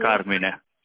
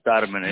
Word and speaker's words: Carmine: Italian way both is Carmine. – Carmine, okay. – Carmine, yeah, I'm Italian.